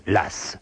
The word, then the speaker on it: Law: D'abord, pourquoi prononce-t-on l'As? L'eau, c'est L-A-W.